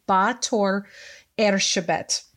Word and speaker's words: Báthory Erzsébet: Her name in English is Elizabeth Bathory. She is a Hungarian countess. So, in Hungarian, forgive my pronunciation, everyone, it's, it's very close to Báthory Erzsébet they say the last name first.